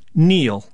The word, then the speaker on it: kneel: K-N-E-E-L, Neil. – Raspberry, answer. – Silent right. letters. – Yes, silent letters. Oh, yeah. Raspberry is... – the silent S... – P. – Or a P. Sorry. – Silent P, right? Or answer... P. – W. – W and Neil. – Okay. – The K, which was silent until I spelled it earlier in the clue.